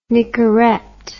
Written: Nicorette

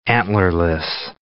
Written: antlerless

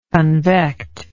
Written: convectively

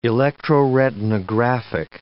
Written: electroretinographic

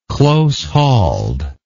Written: close-hauled

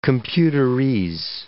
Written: computerese

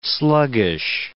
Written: sluggish

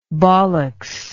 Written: bollocks

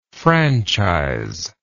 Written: franchisement

